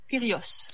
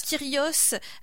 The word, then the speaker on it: Kyrgios: C'est inspirant, mais peut-être pour le côté rebelle du sport, parce que tu vas nous parler euh, de Nick Kyrgios, c'est comme ça qu'on le prononce Kyrgios. Kyrgios, euh, qui est considéré comme l'enfant terrible du tennis.